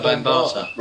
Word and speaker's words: Bambaataa: Africa Bambaataa.